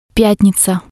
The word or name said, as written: pyatniza